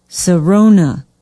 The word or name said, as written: Sarona